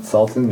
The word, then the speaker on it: Sultan: Sultan, yeah.